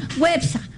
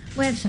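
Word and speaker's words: Güepsa: ¿Cómo se dice a los que nacieron en Huespa? Perdón, Huepsa. Websa. ¿Ah? Con diéresis.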